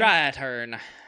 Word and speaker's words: Strathairn: Strathairn.